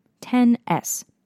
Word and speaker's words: XS: Following in Apple tradition over the years, the X is actually a Roman numeral, so it's pronounced 10, as in the number, and followed by S, as in the letter. Think 10S.